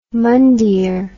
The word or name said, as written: mandir